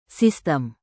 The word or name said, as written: sistem